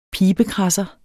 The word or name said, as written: pibekradser